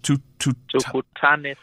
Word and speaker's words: Tukutane: Tutane Thursday. What how do you pronounce that? – Tukutane Thursday. Tukutane Thursday simply means let's meet on Thursday.